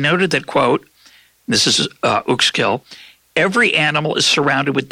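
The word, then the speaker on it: Uexküll: It's Uxkull. Uxkull, I guess. – Uxkull.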